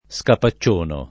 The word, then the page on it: scapaccionare: lingua italiana